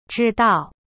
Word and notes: 知道: zhi-dao

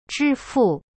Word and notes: 支付: zhi fu

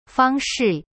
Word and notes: 方式: fang shi

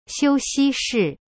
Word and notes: 休息室: xiu-xi-shi

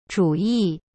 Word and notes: 主意: zhu yi

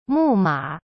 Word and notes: 木马: mu ma

